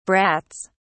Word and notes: brats: British English

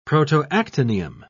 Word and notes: protoactinium: British English

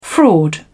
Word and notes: fraud: British English

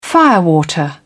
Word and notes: firewater: British English

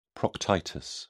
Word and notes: proctitis: British English

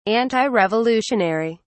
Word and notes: antirevolutionary: British English

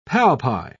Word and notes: palpi: British English

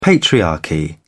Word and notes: patriarchy: British English

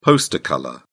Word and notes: poster colour: British English

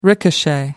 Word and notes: ricochet: American English

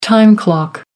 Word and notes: time clock: American English

